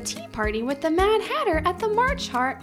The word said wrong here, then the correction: March Hare: The March Hare is going.